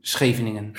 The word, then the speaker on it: Scheveningen: Is it in Schwenningen as well? Or? Yeah, yeah, yeah. It, mostly it all happens in Scheveningen.